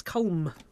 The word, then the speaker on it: Colm: Yeah, is he Colm? – Colm, I've never worked it out, sorry. I've just gone phonetically. – It's terribly embarrassing. – No, it is. I think you're right. I think it is Colm.